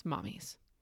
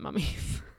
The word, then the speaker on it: mummies: Case in point, Friday night, he asked me to teach him about mummies, which he likes to pronounce mommies. – Yeah, and he asked me what happens when the mommies come. – Yes, he meant mummies.